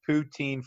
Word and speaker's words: poutine: I would love to do a poutine food truck and people are probably getting like not hearing me say that right. P-O-U-T-I-N-E, poutine, right?